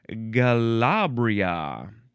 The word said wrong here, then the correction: galabia: You can spell it G-A-L-A. Oh, no, actually, Galabia. There's no R. Galabia. G-A-L-A-B-I-A or G-A-L-A-B-I-E-H or G-A-L-A-B-I-Y-A.